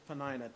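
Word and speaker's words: Peninnah: You may have heard that pronounced Penina, but I've always heard it pronounced Penina, and so I'm going with Penina tonight.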